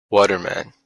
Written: Waterman

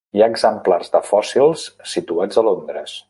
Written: exemplars

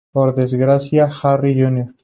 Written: desgracia

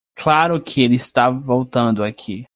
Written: voltando

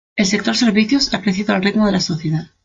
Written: sector

